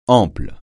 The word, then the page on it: ample: francais